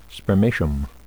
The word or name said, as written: spermatium